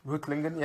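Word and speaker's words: Reutlingen: Rutlingen. – Rutlingen, yeah.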